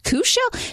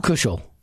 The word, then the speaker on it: Cashel: She has it K-U-S-H-E-L-L. – Kushel. – Kushel? – Kushel. – Kushel. – Well, does it say it? Didn't it say the pronunciation? – She spells it C-A-S-H-E-L. – Kushel. – Oh. – But she puts in parentheses K-U-S-H-E-L-L.